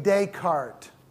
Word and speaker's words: Descartes: The first one is René Descartes. D-E-S-C-A-R-T-E-S. Okay, pronounced Descartes, not Descartes. Descartes? No. Descartes, everybody say that with me. Descartes. Yeah, no S at the end, just Descartes.